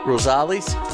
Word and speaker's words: Rosales: How do you pronounce that? R-O-S-A-L-E-S. Rosales? – Rosales. – Rosales. – Okay, Rosales. – That's good.